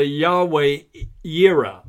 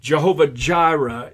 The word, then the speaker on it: Yahweh Jireh: But in Hebrew, it's actually Yahweh Jireh, or Jehovah-Jireh is the more popular pronunciation, but it can't be the way it was pronounced initially because there was no J in the Hebrew language. It was Yahweh-Jireh.